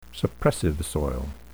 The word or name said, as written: suppressive soil